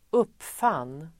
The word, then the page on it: uppfann: [²'up:fan:]